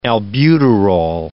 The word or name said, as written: albuterol